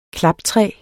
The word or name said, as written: klaptræ